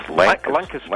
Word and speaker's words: Lancaster: Found out this week that they can't pronounce Lancaster properly in Pennsylvania. It's Lancaster.